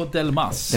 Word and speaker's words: Delmas: Nu går vi in på Delmas. – Den här kan du uttala också. – Delmas, ja. Ja. Eller Delma då, som gäller. Som nej, det är S på slutet. – Ja, men precis. Delma.